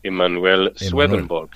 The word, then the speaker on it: Emanuel Swedenborg: Habrá que buscar, ya sabéis, oyentes, meteros en internet, buscar esa página de Manuel Swedenborg, ¿lo digo bien? – Emanuel Swedenborg, sí.